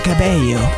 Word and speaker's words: Cabello: Si dice Cabello. Eh, vedi? – Eh, vedi sì, la eh, pronuncia sì. è spagnola è proprio.